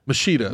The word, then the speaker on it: Machida: Machado. – Am I saying that wrong? – Machida? – Machida, not Machado.